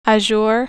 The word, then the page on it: azure: American English